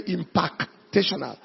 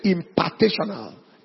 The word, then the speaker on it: impartational: Now, those of you at the media listening to the pronunciation, I didn't say impactational. I say impartational.